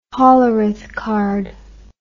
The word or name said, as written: hollerith card